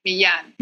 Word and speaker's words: Milan: It's pronounced Carolina, and the last name is Milan. – Carolina Milan. So is the L is silent.